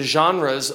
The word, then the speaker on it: genre: It's spelled G-E-N-R-E, but we pronounce it genre. So G-E-N-R-E. You want to say it if you read English, you want to say genre, but it's genre.